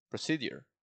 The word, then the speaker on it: procedure: Procedure. Procedure. Procedure. That's how you pronounce it. Procedure. Procedure. Procedure. J, J, like a J. Procedure. – Procedure. – Procedure. Pra, like a schwa, pra, procedure. Procedure.